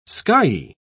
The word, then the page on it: skyey: British English